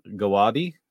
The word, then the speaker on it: Gawade: And I am joined today by Sucheta Gawadi. Is that pronounced correctly there? – That's correct.